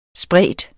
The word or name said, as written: sprede